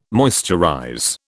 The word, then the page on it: moisturize: British English